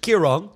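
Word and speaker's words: Ciarán: En toch even over storm Kiran dat spreek je dus uit als Kiran, terwijl je het dus anders schrijft. Het is namelijk een Ierse naam.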